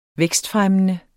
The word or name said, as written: vækstfremmende